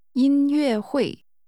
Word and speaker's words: 音乐会: Concert, or literally, music meeting, is pronounced with a high-level tone. – 音 followed by two falling tones. – 音乐会。再说一次。 – 音乐会。